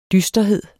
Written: dysterhed

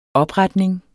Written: opretning

